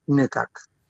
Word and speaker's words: Nukak: E lei riporta la domanda di un membro di Nukak, si pronuncia così il nome della popolazione? – Sì, è più o meno un po' difficile, ma sarebbe una specie di nasale, Nukak.